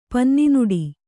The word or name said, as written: ಪನ್ನಿನುಡಿ